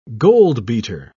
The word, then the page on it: goldbeater: British English